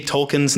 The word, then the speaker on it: Tolkien: Tolkien, which by the way, I had to Google, I've always said Tolkien. My entire life, I said Tolkien. And I decided this morning to Google, how do you say Tolkien's name, and find out that it's actually Tolkien.